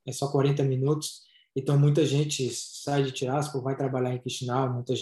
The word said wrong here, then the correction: Tiraspol: Tiraspol é a pronúncia correta.